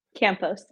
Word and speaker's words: Campos: Luckily, though, we do have Ashley Campo. Did I say that right? – Campos, yeah. – Okay, yeah, Campos, sorry.